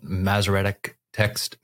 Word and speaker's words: Masoretic: So, am I jumping ahead a little bit by asking you to define what Masoretic text means, if I'm pronouncing that correctly? – That's correct. Masoretic text.